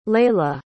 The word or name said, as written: Lailah